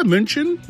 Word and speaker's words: München: We're learning about München. Did I say that right? – Yeah, München.